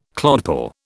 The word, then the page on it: clodpoll: British English